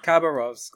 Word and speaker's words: Khabarovsk: Khabarovsk, there you go.